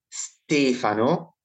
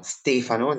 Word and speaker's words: Stefano: So my first name would be pronounced as Stefano. So the accent on the first syllable, you know, Stefano, not Stefano.